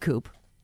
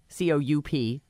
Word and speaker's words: Coup: It's pronounced organic coop. – It's not. Thank you, though, for your time. – Mm-hmm. – It's spelled coop. COUP, but it's coup, like a coup d'etat or something.